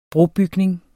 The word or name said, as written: brobygning